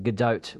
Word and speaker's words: Gadot: And I was thinking it was a silent T, but she's not French; she's Israeli. So it is actually Gal, as in ball, I guess, and Gadot with the pronouncement of the T at the end.